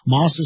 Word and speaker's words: Maus: It's Moss is spelled, of course, M-A-U-S.